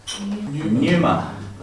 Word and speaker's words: pneuma: Pneuma. – Can you spell it, please? – P-N-E-U-M-A. – Yes. It has a silent P at the beginning.